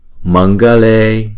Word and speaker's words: Mangale: Nirmale, Mangale. Chữ này cũng vậy, chữ G này chúng ta đọc là G. Thì chữ Anuswara này chúng ta đổi thành chữ Ng.